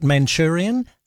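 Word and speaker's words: Mancunian: Well, he certainly has kind of, what do you guys call it, Manchurian? Is that how you say it? – Yes, that's right.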